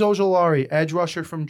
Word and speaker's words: Ojulari: Azeez Ojulari, look up what happened to him at the draft, please.